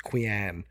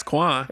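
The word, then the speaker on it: quean: Queen is spelled Q-U-E-A-N. I don't know why it's not spelled queen. It's Quan. – That's Quan.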